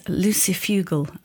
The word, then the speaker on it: lucifugal: So my new favourite word is lucifugal.